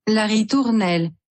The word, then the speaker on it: La Ritournelle: La Ritonelle. Don't even know what it means. It means La Ritonello. – La Ritournelle.